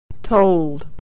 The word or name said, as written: told